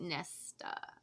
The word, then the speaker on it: Nesta: Her back arched slightly at the way he said her name, the way he bit out the second syllable. So you know he was like, Nesta.